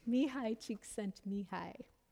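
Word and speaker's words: Mihály Csíkszentmihályi: These jive with the, the research on the psychology of creativity that has been done by Professor Mihaly Csikszentmihalyi, and I like to say that name as often as possible because I did live in Hungary, so I know how to say it. Mihaly Csikszentmihalyi.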